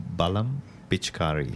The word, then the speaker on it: Balam Pichkari: How do I pronounce the title again? Balam. – Balam Pichkari. – Pichkari.